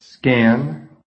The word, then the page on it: scan: American English